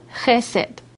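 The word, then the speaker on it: Chesed (חסד): 这个慈爱这个字眼呢，我们可以从原文圣经来查出来啊，它的编号是 2617，希伯来文的发音叫做 Chesed。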